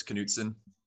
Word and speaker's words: Knutson: I pronounce the K, so it's Knutson.